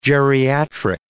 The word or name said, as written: geriatric